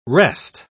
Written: wrest

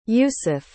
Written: yusef

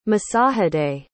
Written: masahide

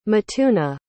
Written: mituna

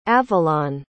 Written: avilon

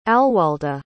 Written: alwalda

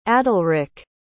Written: adalrik

